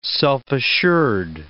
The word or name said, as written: self-assured